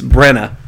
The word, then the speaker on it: Branagh: I had to look up the pronunciation. It's Branagh because I have it written out. Branagh.